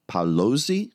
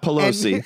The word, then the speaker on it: Paolozzi: He's knighted Sir Eduardo Paolozzi. I think that's how you say it. Not Pelosi.